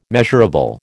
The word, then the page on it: measurable: American English